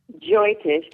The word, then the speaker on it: Jyotish: Since she uses an Indian system of astrology called Jyotish, how do you say that? – Jyotish, correct.